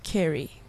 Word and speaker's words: Ceri: C-E-R-I. – Well, yeah. – If she's the same one that was on the Unview, it's pronounced Kerry.